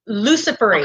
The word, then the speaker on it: luciferase: It's luciferase. – Okay. – Luciferase. So initially I understood luciferase. – Well, that's okay. – Right. Okay. But it's spelled like Lucifer, the name, L-U-C-I-F-E-R-A-S-E, Luciferase.